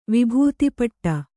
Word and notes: ವಿಭೂತಿಪಟ್ಟ: vibhūti paṭṭa

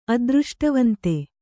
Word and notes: ಅದೃಷ್ಟವಂತೆ: Kannada

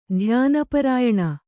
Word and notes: ಧ್ಯಾನಪರಾಯಣೆ: kannada

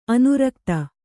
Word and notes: ಅನುರಕ್ತೆ: anurakte

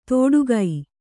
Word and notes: ತೋಡುಗೈ: Kannada

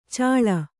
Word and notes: ಚಾಳ: cāḷa

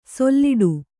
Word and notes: ಸೊಲ್ಲಿಡು: solliḍu

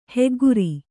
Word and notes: ಹಗೆಗಾರ: hagegāra

